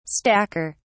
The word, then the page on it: stacker: British English